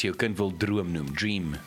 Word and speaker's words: Jream: But they spell it with a J. – Wat? – Ja, dream. – Waar is die? – Not dream, dream. So it's a J. – Spel hem? – J-R-E-A-M. Like dream, maar net dream.